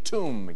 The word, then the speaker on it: tomb: The silent letter in a four-letter word for a mausoleum. – What is B? – B in tomb, yes.